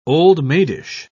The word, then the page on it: old-maidish: British English